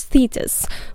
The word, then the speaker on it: Thetis: Among these daughters was Thetis, which is a different person from Tethys the titan and mother of Doris, who I just mentioned. It's written differently, but pronounced almost the same.